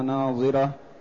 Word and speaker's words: ناظرة: التي النضارة والجمال والبهاء والحسن، وهي تنطق بالضاد، والأخرى تنطق بالضاء التي من النظر. نعم. – أي حسنة بهية مشرقة مسرورة إلى ربها ناظرة، أي تراه عيانا، كما رواه البخاري رحمه الله تعالى في صحيحه: إنكم سترون ربكم عيانا.